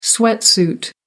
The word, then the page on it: sweatsuit: American English